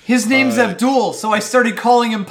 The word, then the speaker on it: Abdul: And she's like, well, when I asked him how to pronounce his name, he said Abdul, like Paula Abdul.